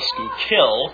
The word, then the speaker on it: Schuylkill: Perhaps the Schuylkill, with its ominous-sounding last syllable, Schuylkill.